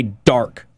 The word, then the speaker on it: DXRK: And it's by Dark, but they spell it D-X-R-K.